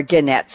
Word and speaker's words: Gannett: Gracia Martore at Gannett, or Gannett, some people pronounce it, Marissa Mayer at Yahoo, Sherry McCoy at Avon Products.